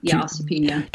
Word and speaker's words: osteopenia: Osteopenia. Is that the correct? Am I pronouncing that correctly? – Yeah, osteopenia.